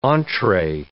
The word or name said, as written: entree